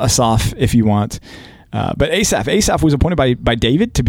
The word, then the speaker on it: Asaph: We have a new psalm writer here, and it's Asaph, is how my computer pronounced it, at least. – Yeah, I mean, that's the emphasis is on the last syllable, usually.